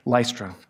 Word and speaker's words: Lystra: Lystra.